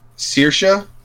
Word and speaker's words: Saoirse: So, that name is pronounced Saoirse. – I'm sorry, Saoirse? – Yep. – S-A-O-I-R-S-E? – Yep. – Saoirse? – Saoirse. – Saoirse. – It's Irish.